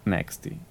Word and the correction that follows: Netsky: Ah, Netsky.